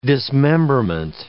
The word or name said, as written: dismemberment